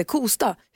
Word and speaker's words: Kosta: Det heter alltså Lovika, det heter Ramlösa och det heter Kosta.